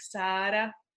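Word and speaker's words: Sára: Well, in Czech, it's spelled S-A and then the accent's above the A, which means it should be like long. So it's like Sara.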